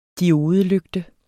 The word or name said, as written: diodelygte